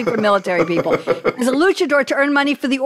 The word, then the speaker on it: luchador: Am I saying that right, Noble? Is it luchador? – Yes, ma'am.